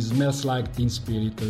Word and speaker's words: Smells Like Teen Spirit: Ciao Peppe, oggi parliamo quindi di Smells Like Teen Spirit dei Nirvana. – Cazzo, come l'hai detto bene, aspetta, ripetilo. – Smells Like Teen Spirit.